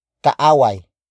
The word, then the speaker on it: kaaway: And that is kaaway. Kaaway. Kaaway. Kaaway is spelled as K-A-A-W-A-Y.